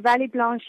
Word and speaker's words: Vallée Blanche: is it Vallée Blanche? – Yes, absolutely. So the Vallée Blanche, it's not a ski piste as such.